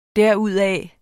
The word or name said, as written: derudaf